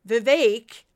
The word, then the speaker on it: Vivek: Apparently, it's pronounced Vivek, like cake, Ramaswamy.